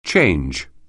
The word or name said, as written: change